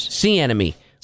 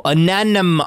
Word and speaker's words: anemone: Sea enemy. – Like, sea enemy. – Like a shark to a seal. – No, anemone. Oh, God damn it. – I don't know how to say that word. – A-N-E-M-O-N-E.